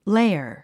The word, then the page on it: lair: léə r; レァ